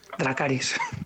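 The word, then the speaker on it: Dracarys: Eh, Dracaris, ¿no? – Se dice así, ¿verdad? Eso, Dracaris.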